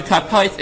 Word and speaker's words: kapois: Kapois. It's written kapas. It's written chaser. So even though it's pronounced kapois, right?